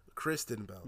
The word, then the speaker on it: Kristen: Kristen Bell.